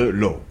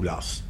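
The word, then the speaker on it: Law: L'un, c'est l'émeute de la rue Vivienne et la banqueroute de l'Or, ou l'As, puisque les deux prononciations sont adoptées.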